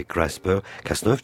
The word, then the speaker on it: CRISPR-Cas9: avec l'accent ou la prononciation anglaise, puisque de toute façon c'est l'acronyme d'un mot anglais, c'est CRISPR-Cas9,